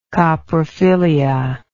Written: coprophilism

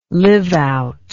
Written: live out

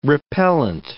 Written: repellant

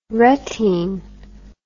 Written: retine

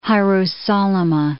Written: Hierosolyma

